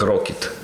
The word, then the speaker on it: rkt: Э, в английской буквы RKT. Читается как Rocket оказывается.